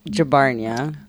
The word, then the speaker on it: Jabbarnia: Jabbarnia.